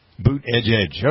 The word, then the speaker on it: Buttigieg: He would say yeah. Boot Edge Edge. – Boot Edge Edge.